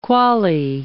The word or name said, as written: quale